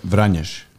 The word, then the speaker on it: Vranjes: Say the full name for us, because some people don't know how to say it. – Should I say how I'm supposed to say? – Yeah. – Vranjes.